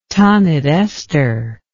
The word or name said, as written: Taanith Esther